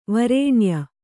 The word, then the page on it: ವರ್ಣೀಯ: varṇīya